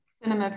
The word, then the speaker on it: Cinema Paradiso: Cinema Paradiso, or there's the Italian pronunciation, but I can't roll my R's, so I can't say it. So. – Yeah, the C in Italian would be Cinema.